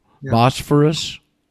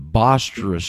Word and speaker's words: Bosphorus: Some of you, Bosphorus? – Yep. – The B, that starts a B, not a V, the Bosphorus Straits.